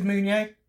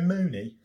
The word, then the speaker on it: Meunier: How do you pronounce his name? – I think it's Meunier. – Meunier. Let's go for Meunier. I was going to go Mooney. Doesn't sound as good, does it?